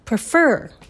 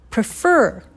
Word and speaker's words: prefer: I will go with をくちゃっとくっつけて、 I will go with go を一番強く発音してみましょうそして次 prefer prefer 二つ目の音節にストレスを置きまし